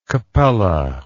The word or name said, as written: kpelle